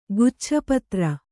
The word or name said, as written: ಗುಚ್ಛಪತ್ರ